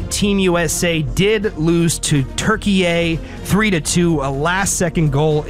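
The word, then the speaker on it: Türkiye: Yeah, it's Turkey, but everybody's pronouncing it Turkey A. All the announcers, I think that's the proper pronunciation for the country, so I decided to go with that pronunciation.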